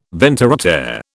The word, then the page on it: ventre à terre: British English